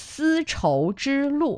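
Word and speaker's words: Si Chou Zhi Lu (丝绸之路): It is four characters and it is Si Chou Zhi Lu.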